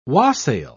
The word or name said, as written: wassail